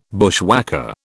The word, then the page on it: bushwhacker: British English